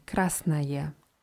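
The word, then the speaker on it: красное: O geschrieben, aber dieses O am Ende wird eher wie A ausgesprochen. Krasnaje.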